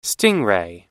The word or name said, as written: stingray